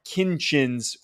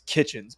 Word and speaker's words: Kinchens: I think that's that's obviously the way that you pronounce it. But I always make the mistake, and I think a lot of people think it's Kitchens, but it's not.